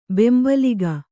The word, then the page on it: ಬೆಂಬಲಿಗ: bembaliga